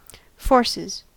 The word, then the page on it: forces: American English